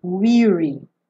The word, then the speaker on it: weary: Essa pronúncia é de I. Weary. Não é do verbo to wear que é aberta.